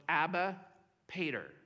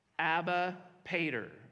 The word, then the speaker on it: Abba Pater: So it's Abba Pater. Would you say that with me? Abba Pater. Okay, so that's the way it's pronounced. I actually went to the internet and made sure exactly how it's pronounced.